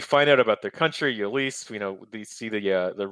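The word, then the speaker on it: Ylisse: Is it Lyssa? How do you say it? – Yalise. I think is how they say it.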